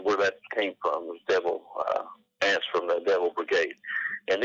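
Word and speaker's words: Devil Anse: which was also known as Devil Ants. Am I saying that right? – That's correct. Devil Ants.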